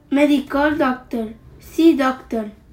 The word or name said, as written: medical doctor